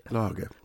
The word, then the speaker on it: Lage: Now, is it Lager or Lager, do we decide? – Lager. – Lager, yeah, L-A-G-E.